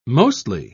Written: mostly

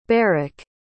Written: beric

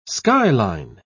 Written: skyline

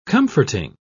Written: comforting

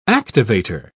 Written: activator

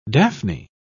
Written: daphne

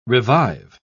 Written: revive

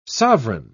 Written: sovereign

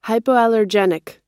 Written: hypoallergenic

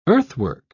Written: earthwork

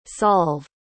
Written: solv

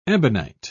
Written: ebonite

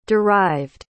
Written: derived

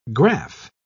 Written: graph

